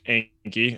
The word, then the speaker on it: Anki: Yeah, so Dave, one thing we did, so AJ, I, and I think Alex, you might be big on Anki, as that is the correct way to say it.